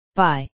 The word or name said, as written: bye